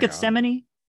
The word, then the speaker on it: Gethsemane: Is it pronounced Gethsemane? – Yeah.